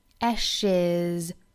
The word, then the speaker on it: ashes: ashes, 加了元音 e 之后呢，它结尾的这个 s 字母呢就应该发成 z 的发音，是一个浊辅音，不要发成 s, 应该是 Ashes, 这里面呢涉及到我们语音里面讲到的这个清清浊浊的概念、啊、因为元音 e 我们放到这个浊辅音里面、啊、 Ashes, 好的，我们再来读一下 Everything seems so golden one minute, then turns to ashes the next.